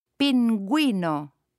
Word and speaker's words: pingüino: If there are two little dots on top of the U, then the U is pronounced. Listen and repeat. – Pingüino.